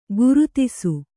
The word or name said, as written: ಕೀರ್ತಿಸು